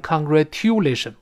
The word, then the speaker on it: congratulation: 你像这里面 t 后面又碰到了字母 u，它发音也是 u，又是半元音 e 跟 o 到一块儿，所以 t 撞见了 e。变成 ch，再读一遍，congratulation，congratulation，所以 congratulation，congratulation 不要读成 congratulation，不对。